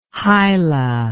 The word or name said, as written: hyla